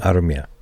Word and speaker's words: Armia: I do like Joel Armia. – Armia. – Shout out to all our Finnish viewers for pronouncing that correctly.